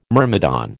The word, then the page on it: myrmidon: American English